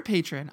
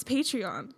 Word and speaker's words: Patreon: Is it Patreon or patron? I'm dumb. – It's Patreon. – That would make sense. – Phonetically. I thought it was patron, like, oh, you're a patron for somebody, but everyone on the internet says Patreon.